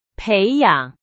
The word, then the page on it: 培养: Chinese